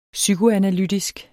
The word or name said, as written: psykoanalytisk